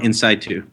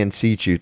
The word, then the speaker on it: in situ: Ductal carcinoma in situ. – Yes, I say in situ, but you know, I don't deal with this every day like you do.